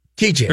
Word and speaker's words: Tite: So the guy's name is spelled T-I-T-E. Tight. – Oh, tight. – Which is great, but it's spelled Chiche. – Chiche. – Or it's pronounced Chiche. – Chiche. – Yeah. – But it's T-I-T-E. – T-I-T-E.